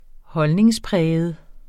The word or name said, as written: holdningspræget